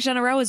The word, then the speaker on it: Généreux: Now Jean-Marc Généreux is back. – Thank so you so much. You are the first American who's ever pronounced Généreux correctly.